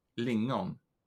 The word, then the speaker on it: Lingon: It's only it's only me that can pr pronounce it right, right in Swedish. Lingon.